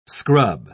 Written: scrub

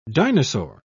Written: dinosaur

